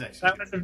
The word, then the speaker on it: Executor: Everybody would say executor, and it drove me crazy. Anyway. – It is executor.